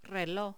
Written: reloj

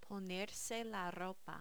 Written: ponerse la ropa

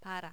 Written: para

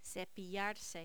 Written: cepillarse

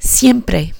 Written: siempre

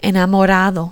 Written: enamorado

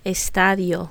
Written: estadio

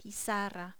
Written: pizarra

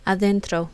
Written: adentro

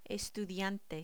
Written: estudiante

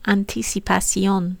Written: anticipación

